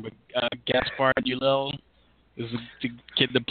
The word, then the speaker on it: Ulliel: There's a weird, yeah, the D is silent. He's French, and it's Ulliel, I believe.